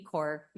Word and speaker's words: Kaur: So my name is Anit Kaur, Your Honor, and may it please the court.